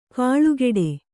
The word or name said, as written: ಕಳೆಗೊಡು